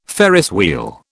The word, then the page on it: Ferris wheel: British English